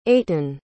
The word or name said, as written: eytan